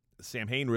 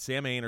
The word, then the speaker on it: Samhain: Versus the correct pronunciation, which is Samhain.